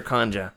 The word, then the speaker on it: Kanja: Is it Kanja or Kanja? – It's Kanja. You gotta say it like the movie.